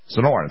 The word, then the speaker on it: Sonoran: My guest from SDI, Sonoran Desert Institute, or Sonoran, SDI. You can pronounce that the same way every day. Yeah, Sonoran, thank you very much.